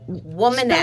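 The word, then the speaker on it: Womaness: Woman S. Spell that for me. Woman. – So Woman, A-N-E-S-S. So W-O-M-A-N. – A-N-E-S-S.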